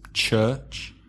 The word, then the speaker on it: church: Be careful of the vowel sound. That's like nurse or turn, church. Where do Muslims pray? – In a mosque. – M-O-S-Q-U-E.